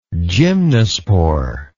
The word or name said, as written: gymnospore